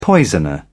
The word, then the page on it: poisoner: British English